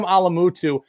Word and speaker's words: Alamutu: Okay, so Sam Alamutu, like a six five, six six.